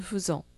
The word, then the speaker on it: faisons: Nous faisons. – Nous faisons. – Vous faites. – Vous faites. – Ils font. – Ils font. – Donc, ここは一個の重要の例がやろうね。 Nous faisons. – Nous faisons. – F-A-I で書くけど、フの発音する。